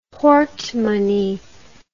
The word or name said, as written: portemonnaie